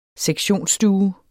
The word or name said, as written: sektionsstue